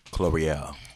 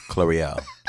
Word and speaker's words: Chloriel: My name is Chloriel. What's up? I'm a doula. – Chlorioso. – Chloriel. Chloriel. I'll tell you how to spell it later. – Is that like L'Oreal? – No.